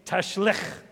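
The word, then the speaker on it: Tashlikh: And it's a fun Hebrew word because you get to make the sound at the end. You want to say it with me? Tashlik. There you go. You got to cough up a lung.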